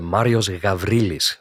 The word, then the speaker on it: Marius Gavrilis: Also wenn du es griechisch aussprechen möchtest, das ist ein griechischer Name, dann muss das R rollen und Marius Gavrilis sagen. Aber das kann ich natürlich nicht von euch verlangen. Deswegen Gavrilis ist ganz wunderbar.